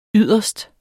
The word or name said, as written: yderst